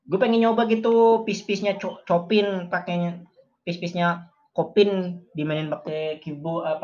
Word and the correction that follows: Chopin: Kopin. Chopin. Ah, emang bacanya gitu? Chopin.